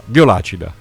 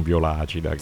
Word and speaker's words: Violacida: Intanto ricordiamo che Violacida si scrive Violacida, cioè con una sola A. – Si pronuncia anche così, come dice, detto. Sì. – Ecco, infatti allora Violacida, che è meglio, esatto. Non Violacida.